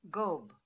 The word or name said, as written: Gob